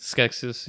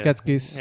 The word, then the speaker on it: Skeksis: how do you say Skeksis? – Skeksis, yeah. – Skeksis. – Yeah, it's fine. – Right, I'll say how I say it. – You have an accent, man. – Yeah, the Skeksis.